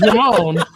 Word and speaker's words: Ramon: But what I was bringing up was we're calling Ramon Roman like multiple times. It's like he couldn't say the name. Matter of fact, say it now. – Why you want me to be? – Say his name. – He said, I like top Roman. – It's Ramon. I got it now. I just didn't have it then.